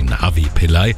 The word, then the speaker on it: Navi Pillay: Die Friedensnobelpreisträgerin aus Südafrika heißt mit vollem Namen Navanita Mpilai, ausgesprochen wird sie Navi Pillay.